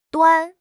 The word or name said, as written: 端